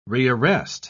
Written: rearrest

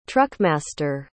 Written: truckmaster

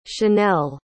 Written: channelle

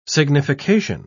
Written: signification

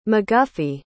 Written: mcguffey

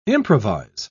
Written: improvise

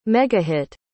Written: megahit

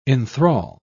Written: enthrall

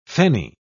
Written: fenny

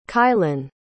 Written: caillen

